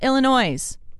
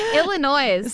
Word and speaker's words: Illinois: Thank you to Sarah in Colorado Springs, Greg in Manitou Springs, Diana in Williamette, Illinois. I'm so sorry. – Illinois.